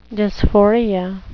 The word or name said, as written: dysphoria